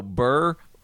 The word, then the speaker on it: Burr: just want to let you know, Burr rhymes with a lot of shit. – Yes. For instance, sir.